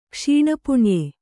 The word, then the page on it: ಕ್ಷೀಣಪುಣ್ಯ: kṣīṇa puṇya